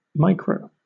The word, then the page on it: micro: British English